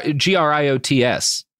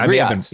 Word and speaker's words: griots: I always thought that was a reference reference to the griots. You know, we're like the African storytellers. – But yeah, yeah, yeah, yeah. It, it's G R G R I O T S. Uh, yeah, the griots.